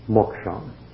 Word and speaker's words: moksha: It is spelled M-O-K-S with a dot below it, or S-H-A, moksha.